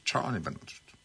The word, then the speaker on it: Bennett: Observa mi pronunciación. – Sí. – Tony Bennett. – Bennett.